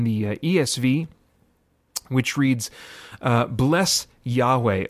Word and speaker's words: Yahweh: There are a number of accepted pronunciations. The one that is very common, very popular, and that I tend to use is, well, I guess a little more technically it would be Yahweh.